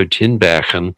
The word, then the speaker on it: Tinbergen: The other thing I would say comes down to some ideas of a Dutch ethologist, Nico Tinbergen. I try to get the Dutch pronunciation. – That was good. That was how to say.